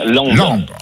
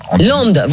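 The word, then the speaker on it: Lempdes: Je ne sais pas si je prononce bien. Bonjour. – Oui, bonjour, bonjour à toute l'équipe. Heureux de vous retrouver. Non, en fait, on dit bien Landes. – Landes, voilà, exactement. – L-E-D-E-S.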